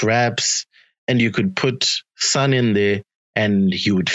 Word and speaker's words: Sun: So what is this one here, the Soon album? – Okay, so first of all, it's called Sun, like the sun, but it's spelled Sun. Sun. It's Sun. Sun